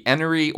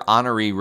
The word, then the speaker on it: Enoree: It's spelled E-N-O-R-E-E, so I personally don't see how you can pronounce it Honoree, but I have heard a lot of locals pronounce it that way.